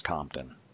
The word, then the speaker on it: Compton: Like, that's how I could get people to know my last name is Compton. Like straight out of.